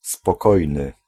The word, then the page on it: quiet: /ˈkwaɪ.ət/